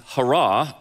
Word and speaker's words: chara: The Greek word that is translated as joy in English is what we would spell as C-H-A-R-A. It's hara, or we might pronounce it chara. And the Greek word that is translated as grace is spelled C-H-A-R-I-S.